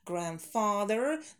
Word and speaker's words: grandfather: Ну, в принципе, у многих отметила этот момент, поэтому для всех grandfather не произносим D. У нас она там silent, немая. Мы ее пишем, но не произносим в этих словечках. Бабушка, дедушка, бабушка и дедушка, grandparents, grandfather,